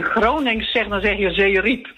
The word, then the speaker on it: Zeerijp: Zeerijp, Zeerijp. Nee. – Nou, als je Zeerijp zegt, dan benadruk je de tweede lettergreep meer dan de eerste lettergreep.